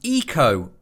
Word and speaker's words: Ico: This week's game is Eco on the PlayStation 2. – Is that how you pronounce it? – Yes, that's how you pronounce it. – All right. – I'll probably slip back into Ico as we go, because I've been calling it Ico.